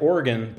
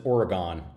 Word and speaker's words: Oregon: like Oregon, but they pronounce it Oregon.